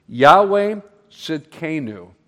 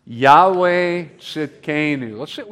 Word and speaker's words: Yahweh Tzidkenu: If you look on your handout, you'll see right underneath it, I put kind of like a pronunciation guide. Yahweh Tzidkenu. Let's all say that together. Ready? Yahweh Tzidkenu. That's good. One more time. Yahweh Tzidkenu.